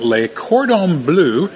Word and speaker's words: Le Cordon Bleu: We're at Le Cordon Bleu School of Cooking.